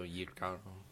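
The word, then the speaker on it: jícaro: Similia de Jicaro? Yicaro? – The J is Y, so Yicaro.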